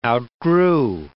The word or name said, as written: outgrew